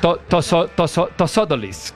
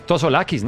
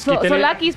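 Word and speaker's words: Tsolakis: Tosodolis. ¿Cómo se vería diciendo esto? No, es Tosolakis, ¿no? Sí, Solakis. – Solakis, porque la T es muda.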